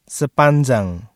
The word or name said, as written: sepanjang